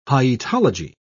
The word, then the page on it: hyetology: British English